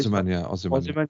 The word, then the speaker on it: Osimhen: Osimhen. – Yes, so you're trying to get Osimhen to compete with Chelsea for 111, 120, it said. Again, rumours. Arsenal will compete with Chelsea to buy Victor Osimhen.